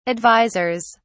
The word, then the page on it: advisors: British English